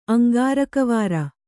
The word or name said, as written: ಅಂಗಾರಕವಾರ